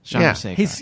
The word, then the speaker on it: Chandrasekhar: Jay Chandrasekhar. Did I say that right? – Yeah. Chandrasekhar.